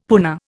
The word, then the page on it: puna: Indian